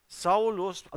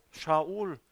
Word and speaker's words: Shaul: Ich habe mal gelesen, die Griechen konnten kein Sch aussprechen. Da haben die aus allem Sch und S gemacht. Es heißt Shabbat und sie sagten Sabbat. Es heißt Shaul und sie sagten Saul.